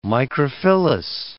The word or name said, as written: microphyllous